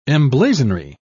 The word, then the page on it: emblazonry: British English